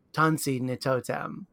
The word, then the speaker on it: Tansi Nitotem: And I would like you to please help me if I pronounce it wrong. I'll do my best, but it's Tansi Nitotem. – Yeah, that's good.